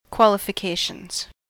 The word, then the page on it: qualifications: American English